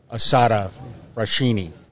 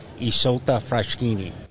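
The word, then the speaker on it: Isotta Fraschini: Most people pronounce the poster car as Assata Fraschini, but in Italian, it's the correct pronunciation is Isolta Fraschini.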